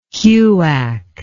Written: huac

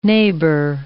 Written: neighbour

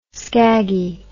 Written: skaggy

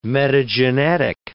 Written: metagenetic

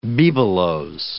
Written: bibelots

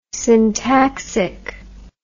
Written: syntaxic

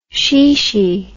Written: shishi

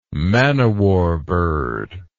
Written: man o' war bird

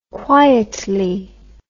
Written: quietly

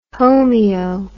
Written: homeo

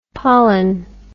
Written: pollan

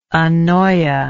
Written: anoia

